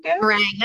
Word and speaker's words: meringue: Meringue? – Meringue. That's right. Nope, meringue is right.